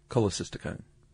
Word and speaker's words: cholecystokinin: And then CCK, which, if I may, cholecystokinin. – Colu. Cholecystokinin.